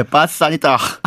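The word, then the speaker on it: pass sanitaire: 아, 불어로 그렇게 발음합니까? 예, 빠싸니다.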